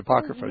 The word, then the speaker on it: Apocrypha: I wanted to find out, what's your opinion about the, excuse me if I don't say this correctly, the Apocrypha? – The Apocrypha, yes.